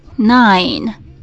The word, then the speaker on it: nine: nine，最后有一个轻轻的 n 的音，舌头顶到上颚，nine，nine，nine。